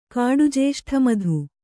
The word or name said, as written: ಕಾಡುಜೇಷ್ಠಮಧು